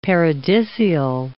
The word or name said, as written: paradisial